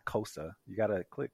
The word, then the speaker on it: Xhosa: It's spelled X H O S A, and the X you X H O S A. – Yeah, so the X and H sound is a click, so it's Xhosa.